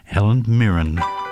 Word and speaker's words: Mirren: If I pronounce this right, I think it's Helen Mirren. – It is. Mirren. Well done.